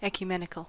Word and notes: ecumenical: American English

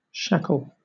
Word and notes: shackle: British English

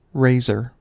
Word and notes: razor: American English

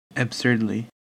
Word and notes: absurdly: American English

